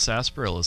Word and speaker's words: sarsaparilla: They want to see him drink sarsaparilla. I don't think I'm pronouncing correctly. – No, that's right. – It's pronounced sarsaparilla.